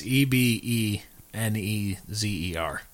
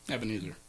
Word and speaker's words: Ebenezer: I think it's E B E N E Z E R. Maybe? – Ebenezer. You hear Vito down there, everybody? – What's he doing? – Four E's and they're all pronounced differently.